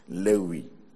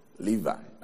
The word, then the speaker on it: Levi: So he was named Levi, or Lewi. In fact, the way the Greek pronounce it is the proper pronunciation of this name, Lewi. And now, or if you want the English, Levi.